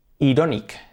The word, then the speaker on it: ironic: Pero no obstante, os voy a dar la pista de cómo se dice icónico. Algo icónico es, se escribe iconic, iconic, y se pronuncia iconic. Ja, buena pista. Iconic, por lo tanto, ironic.